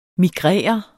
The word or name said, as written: migrere